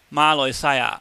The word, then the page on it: mahloihsaia: cantonese